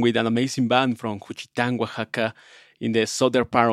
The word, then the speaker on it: Juchitán: Juchitán, Oaxaca.